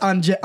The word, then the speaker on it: Angel: So he spelled differently. – Oh, and, it's pronounced Angel.